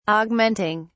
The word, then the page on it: augmenting: British English